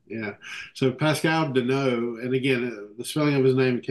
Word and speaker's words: Denault: Yeah, he actually pronounces it Denault. I think I called him Denault forever until I ran across a guy and Bing, it occurred to me he's actually French. So it would be Denault.